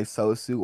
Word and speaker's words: Sosu: So, getting into, like, the names and shit like that, when you're, like, your backup page on Instagram, it's called, hopefully I don't butcher the pronunciation, but it's called Sosu. Is that how you pronounce it? – Sosu.